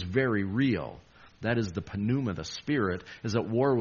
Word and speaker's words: pneuma: The word for spirit is pneuma. Like P is silent when we use it for pneumatic drill, anything to do with air, because that's what spirit was.